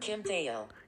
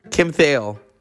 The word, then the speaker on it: Kim Thayil: Kim Thayil. – Kim Thayil.